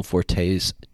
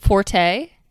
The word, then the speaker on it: Forte: It's 3,600 square feet, but this is a gorgeous home, and I applaud Will Forte's taste. – I do have to call you guys out on your pronunciation, though. – What? – Forte? – You're both saying forte instead of forte.